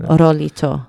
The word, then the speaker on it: Rolito: But Rolito. – Yeah. – Am I saying that right? – Rolito. – Oh. – Like Rolly. I think that's what they're saying.